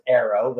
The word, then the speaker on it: Ayro: Okay, so Ayro is actually, it's a Scottish word, and it's pronounced Ayro, literally just like the English word arrow.